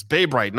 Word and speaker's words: Bay Bright: Bay Bright. It's Bay Bright, not by Bright Bay.